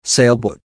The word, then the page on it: sailboat: American English